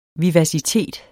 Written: vivacitet

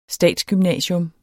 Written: statsgymnasium

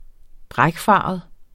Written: brækfarvet

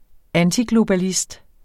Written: antiglobalist

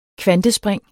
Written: kvantespring

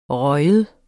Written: ryger

